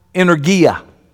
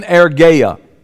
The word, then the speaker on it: energeia: And we used to pronounce it, I would call it energia. Y'all remember that word? Energia. You know, the English word is a Greek word, the English word, you want to guess? Energy. But the correct pronunciation is energeia. That's how you really pronounce it.